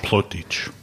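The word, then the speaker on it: Plautdietsch: The film is in Plaut Dutch. Did I say that right? – Plaut Dutch. – Okay, thank you.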